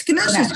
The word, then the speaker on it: Ganesh: It's Ganesh. – Oh, that's Ganesh.